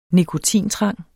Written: nikotintrang

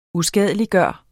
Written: USKADELIGGJORTE

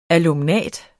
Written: alumnat

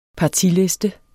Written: partiliste

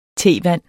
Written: tevand

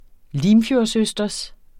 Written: limfjordsøsters

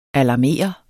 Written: alarmere

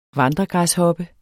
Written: vandregræshoppe

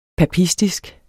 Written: papistisk

